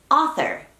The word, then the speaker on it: author: Me encantó este tweet que dice que es como pronunciar osa como española, o sea, ofa. Sí que suena casi igual a cómo se pronuncia author en inglés británico. En inglés estadounidense sería author, en inglés británico, author.